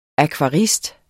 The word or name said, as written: akvarist